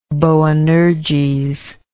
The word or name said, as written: boanerges